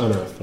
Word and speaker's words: Hunrith: But I think the H is silent, so it's just Unearth.